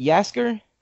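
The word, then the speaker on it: Jaskier: is it Yasker, is that how you pronounce yeah. – it? Yeah, I liked Yasker, I thought he was good, I thought he was great comedy relief.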